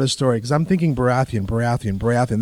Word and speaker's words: Baratheon: Week, and I was listening to the Game of Thrones Clash of Kings, and the reader pronounces Baratheon, Borathian, and immediately it clicked in my head exactly what Greg said.